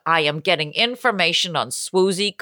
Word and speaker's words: Swoozie: She got her first name, Swoozie, which rhymes with Lucy rather than Woozy.